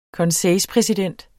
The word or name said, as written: konseilspræsident